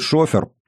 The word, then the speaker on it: шофер: Сидящий за рулем одного из грузовиков, будто простой шофер, с ударением на первый слог, как принято в этом времени, разумеется, Сергей размышлял.